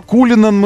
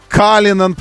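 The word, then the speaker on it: Cullinan: То Кулинан мы должны называть теперь Калинан, потому что так они произносят в своем Роллс-Ройсе это название.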